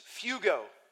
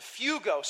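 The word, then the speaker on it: phugo (φεύγω): It's phugo. Okay, sounds like fire, okay, which maybe is appropriate in some regards, okay? But phugo, so phonetically, F-E-W-G-O, okay, phonetically for like the layman Greekist, okay?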